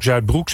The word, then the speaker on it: Zuidbroek: En ik hoor ook nog wel eens Zuidbroek in de verkeersinformatie. Maar dat moet dus ook Zuidbroek zijn.